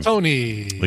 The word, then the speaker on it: Colantoni: Colantoni, the guy who's we are so happy.